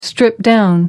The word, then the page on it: stripped-down: American English